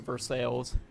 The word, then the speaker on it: Versailles: Wait, as long as, like, there's a place, there's a place in Ohio called Versailles where they all pronounce it Versailles.